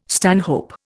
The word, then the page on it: stanhope: Indian